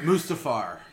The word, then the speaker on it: Mustafar: Mustafar? – Mustafar? – Mustafar, yeah.